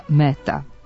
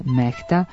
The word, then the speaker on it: Mehta: Na hindi jeziku, koji je jedan od službenih u Indiji, odakle je Mehta rodom, njegovo ime zaista se izgovara Mehta, a pisani oblik preuzeli smo iz engleskog. Međutim, ako se uzme u obzir pravopisna preporuka da strana imena koja su ušla u naš izražajni običaj ne treba menjati, onda ime čuvenog dirigenta treba da ostane Mehta, jer ga tako izgovaramo više od pola veka.